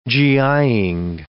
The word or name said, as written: GI'ing